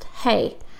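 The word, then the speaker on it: hey (ה): Beginning in verse 33, we have the Hebrew alphabet letter H-E, which is pronounced hey.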